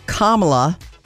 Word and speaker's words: Kamala: It's pronounced Kamala like the pronunciation mark. So think of comma.